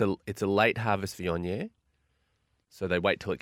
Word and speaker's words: Viognier: Viognier. – Viognier, thank you.